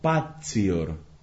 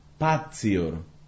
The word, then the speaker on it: patior: Y ese verbo es el verbo patior, patior, que precisamente se pronuncia algo así como patior, como la Z italiana, patior.